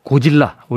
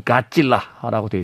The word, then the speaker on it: 고질라: 고질라 우리는 이렇게 부릅니다만 스펠링상으로는 가질라라고 되어 있습니다.